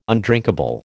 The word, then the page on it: undrinkable: American English